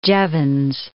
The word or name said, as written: Jevons